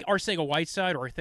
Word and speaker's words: Arcega-Whiteside: Arcega-Whiteside a little bit in that mold where I think Arcega-Whiteside, which sounds like I have a lisp, but that's just how you're supposed to pronounce his name, apparently, because it's spelt with a C.